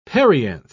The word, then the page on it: perianth: British English